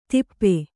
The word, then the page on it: ಥಪ್ಪಿ: Kannada